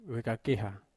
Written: vekakeha

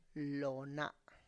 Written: lo na

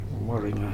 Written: Moriga

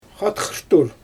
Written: xatxaʃtur

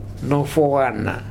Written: nofoana

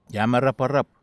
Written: iamaraparap

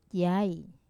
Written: yai

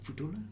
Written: Futuna

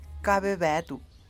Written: kabebeDuu

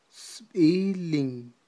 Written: sb'ilin

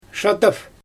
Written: ʃadaf

